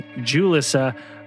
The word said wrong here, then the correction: Ulyssa: Now I figured it out. It's Ulyssa.